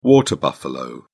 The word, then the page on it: water buffalo: British English